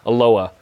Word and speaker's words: Aloha: I pronounce it like the city in Oregon. – The Aloha.